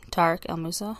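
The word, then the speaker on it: Tarek El Moussa: Is that how you say his name? Tariq El Moussa? The guy from HGTV? I'm getting a nod, yes.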